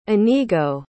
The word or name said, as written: inigo